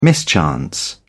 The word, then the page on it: mischance: British English